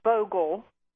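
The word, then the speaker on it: Vogtle: Now, tell us further here about what's going on in South Carolina and Georgia with the two commercial reactors there, the Votel. How do you talk about it in Georgia? – Vogel. The T is silent. – Vogel. – And then you don't pronounce the T.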